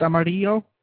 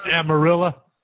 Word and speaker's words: Amarillo: So it's pronounced Amarillo. It's not pronounced Amarillo? Like the Mexicans? – Amarillo? – Yeah, Amarillo. – There's a bunch of honkies here, so we just say Amarillo.